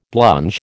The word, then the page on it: blunge: American English